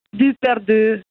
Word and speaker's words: Duperdu: Si scrive così, ma si pronuncia con la bocca così a cul de galina. Duperdu, alla francese o alla milanese, diciamo.